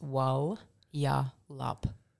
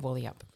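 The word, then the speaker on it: Walyalup: It's actually pronounced Wal-ya-lup instead of Wally-up.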